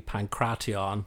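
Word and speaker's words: pankration: Either way, either with a hard T, pancration.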